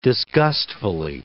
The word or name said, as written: disgustfully